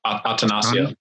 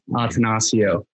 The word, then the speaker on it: Attanasio: So we're we're both from the same hometown, um, and I see you, Ryan Braun, Jared Goff, Mike Moustakas, and Mike Ostanio. Attanasio. Uh, yeah, I just had a little trouble pronouncing that. Attanasio.